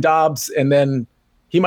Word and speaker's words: Doubs: Derek, there is a controversy on how to pronounce Romeo's name. So maybe we just go with Romeo. Most people say Doubs. I believe it's Doubs.